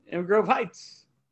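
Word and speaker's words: Inver Grove Heights: Also, I might be saying this wrong. Invergrove? – Oh, yeah. Invergrove Heights.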